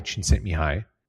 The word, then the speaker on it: Csikszentmihalyi: A Hungarian-American psychologist, this guy, Mihai Csikszentmihalyi, took a while to learn how to pronounce that.